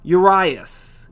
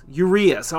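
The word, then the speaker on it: Urías: Julio Urias. Urias. I always mess it up if I didn't listen to someone say his name again.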